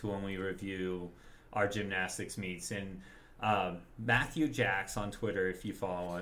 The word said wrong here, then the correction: Jakes: him, or Jakes is how it's pronounced, excuse me.